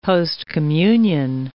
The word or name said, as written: post-communion